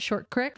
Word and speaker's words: Short Creek: You live in Short Creek, how the locals say it, Short Creek. That's right, Short Creek.